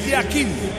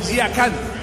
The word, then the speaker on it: Yakin: Uno es pronunciación suiza y la otra pronunciación turca. En el suizo se pronuncia así: Murat Yakin. En turco, Yakan. Cambia la última parte de su apellido.